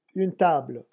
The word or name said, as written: un table